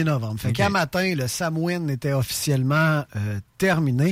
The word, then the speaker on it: Samhain: Ça se prononce le Samhain. – Samhain? – Le Samhain. Je pensais que ça se prononçait le Samhain, parce que c'est de même que ça s'écrit. Mais ça s'écrit S-A-M-H-A-I-N.